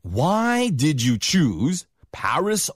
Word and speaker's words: Paris: 미국식 발음은 파리가 아니고 P-A-R-I-S, Paris. – 네, 여러분이 알고 있는 그 악동 있잖아요. Paris Hilton 할 때 그 바로 Paris 이 발음 똑같습니다.